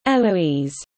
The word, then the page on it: eloise: British English